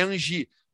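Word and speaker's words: Anzhi: A gente falava, assim, falava assim, Anzi, Anzi. Né? – A gente falava é. Anzi na época. A pronúncia correta é Angi.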